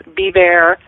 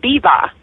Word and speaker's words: Biewer: It's pronounced many different ways by Americans Beaver, Beaver, Beaver. The uh, Germans pronounce it Biva.